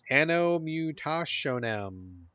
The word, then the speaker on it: Anno Mutationem: Anno Mutationem.